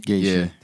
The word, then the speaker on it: Gaethje: Gaethje.